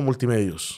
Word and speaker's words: Multimedios: director general de noticias del grupo Multimedios.